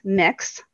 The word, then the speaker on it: Mx: Well, there is a new one that is gender neutral, which is pronounced mix.